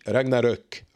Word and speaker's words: Ragnarok: S'ils sortent aussi God of War Ragnarok, d'ailleurs, ça se lit Ragnarok, pas Rock, mais Ruck, si vous voulez prononcer comme il faut.